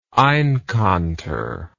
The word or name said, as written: einkanter